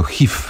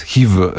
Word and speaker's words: HIV: Które w Polsce zdecydowanie wymawiamy jako HIV z takim polskim F, ale tak naprawdę to jest HIV z wyrazistym V.